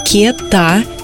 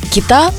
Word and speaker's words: кета: Часто ее называют как кита, но вообще-то ударение правильно ставить на первый слог – кета есть прекрасная запоминалка. Я купил сегодня кету, завернул ее в газету.